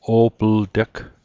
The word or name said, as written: o'plteg